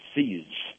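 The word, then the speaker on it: Pzizz: It's an app called Pziz, is how it's pronounced. It's P-Z-I-Z-Z.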